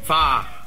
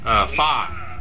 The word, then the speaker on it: far: Far một âm thôi, không có far. Far là nó sẽ càng lúc sẽ càng sai á, đọc là far, phía sau là r, phía sau r nhẹ cái thôi là được. Far, ừ, chỉ cần cong lưỡi là được.